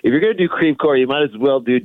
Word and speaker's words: Crevecoeur: We'll talk about our hometown of Crevecoeur, which is the worst French pronunciation of those words ever. Crevecoeur.